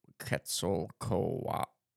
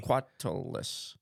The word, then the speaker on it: Quetzalcoatlus: Quetzalcoatlus has 5 syllables in it. – Quetzalcoatlus. – That's what it says. Because it's really difficult to say properly. – Quetzalcoatlus. – So if you say it with more or less than 5 syllables, you're saying the name wrong.